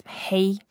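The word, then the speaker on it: hey (黑): And hey is very easy to pronounce. It's like hey. – Okay. – And yeah, without getting so excited. – Yeah. – And then just hey is the first tone, so it can be flat and very cool.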